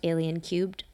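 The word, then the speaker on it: Alien³: It's pronounced Alien Cubed.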